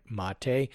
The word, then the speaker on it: Mate: Concerning the pronunciation of M-A-T-E, I believe it should be pronounced the way Larry used to say it, mate.